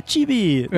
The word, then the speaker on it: Chibi: O Chibi Studio se escreve Chibi, C-H-I-B-I, Chibi, que é, significa pessoa pequena em japonês.